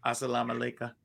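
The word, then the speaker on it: Assalamu alaikum: Salam Aleika. Did I say that right? – And it's Assalam Aleika.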